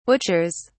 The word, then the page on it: butchers: British English